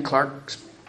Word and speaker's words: Clerke: Clark's the British pronunciation of C-L-E-R-K-E.